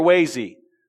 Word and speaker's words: Waze: Does anyone know how to correctly pronounce that? Is it Waze or Waze? Waze. Okay, good.